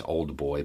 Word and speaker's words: old boy: Now, in the South, you could also say old boy, which is O-L-D. It's old boy, but we don't pronounce the D.